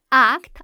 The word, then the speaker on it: ACT: This audio guide is based on Acceptance and Commitment Therapy, pronounced ACT as a single word.